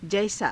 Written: jaisaa

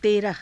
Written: teraha

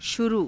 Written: shuruu

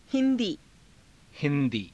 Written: hi^dii

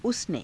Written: usane

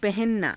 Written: pahananaa

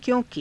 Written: kyo~ki

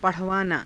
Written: pa_rhavaanaa

